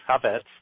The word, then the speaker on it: Chafets: and it's spelled Chafets, but it's pronounced Chavetz.